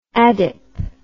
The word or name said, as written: adip